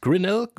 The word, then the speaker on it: Grinnell: Weil gelandet sind sie in Grinnell, spricht man das so aus? Grinnell, genau.